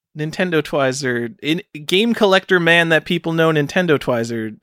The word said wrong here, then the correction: Twizzer: Is that how you pronounce his name? He voted for Twizzer. – Me. I gotta say that. – Twizzer. God. – Twizzer. – Damn it. Nintendo Twizzer.